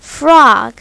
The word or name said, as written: frog